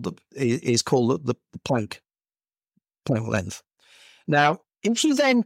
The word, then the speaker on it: Planck: Now, the smallest possible area, according to physics, that you can have is something called the Planck square. Mm-hmm. I use the term Planck, not Planck, because he's German.